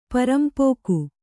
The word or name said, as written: ಪರಂಪೋಕು